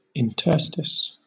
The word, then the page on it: interstice: British English